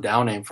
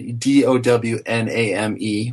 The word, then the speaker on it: Downame: D O W N A M E, which I would pronounce as Downame, mm-hmm. and D O W N H A M, which kind of tends you towards Downam.